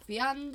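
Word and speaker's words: Wiaan: Vion De Beer.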